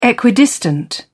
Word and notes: equidistant: British English

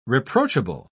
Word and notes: reproachable: British English

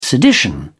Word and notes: sedition: British English

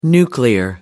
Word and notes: nuclear: American English

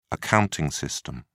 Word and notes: accounting system: British English

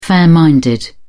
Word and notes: fair-minded: British English

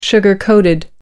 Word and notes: sugar-coated: American English

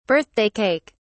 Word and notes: birthday cake: British English

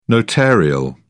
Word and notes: notarial: British English

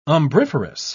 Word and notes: umbriferous: British English

